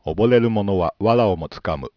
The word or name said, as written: oboreru